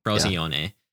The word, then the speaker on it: Frosinone: Frosinone.